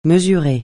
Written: mesurer

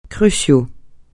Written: crucial

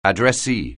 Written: addressee

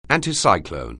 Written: anticyclone

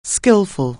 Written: skilful

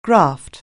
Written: graft

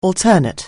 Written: alter ego

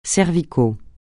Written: cervical

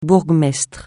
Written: bourgmestre

